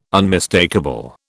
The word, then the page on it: unmistakable: British English